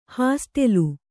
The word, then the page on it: ಹಾಸ್ಟೆಲು: Kannada